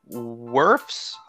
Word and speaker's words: Wirfs: So we have at number 4, Giants taking offensive tackle Tristan Wirfs.